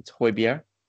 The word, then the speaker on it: Højbjerg: I'm not Danish, but it's Højbjerg.